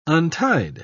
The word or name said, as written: untied